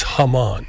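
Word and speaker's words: jamón: But it's spelled Jamon, but it's Hammann.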